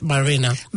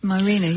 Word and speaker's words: Mirena: I was like, how do you pronounce it? Mirena. – Mirena, yes.